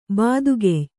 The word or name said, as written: ಬಾದುಗೆಯ್